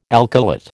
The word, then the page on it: alkylate: American English